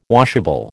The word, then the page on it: washable: American English